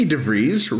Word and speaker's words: DeVries: Weird Al superfans Dave Elvis Rossi and Vicky DeVries, rhymes with cheese, were both instrumental in getting Al cemented on this landmark.